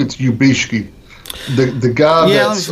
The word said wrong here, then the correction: Yabushige: Yabushige, I think is how it's pronounced.